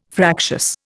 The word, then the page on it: fractious: Indian